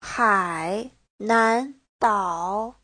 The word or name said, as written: hai3nan2dao3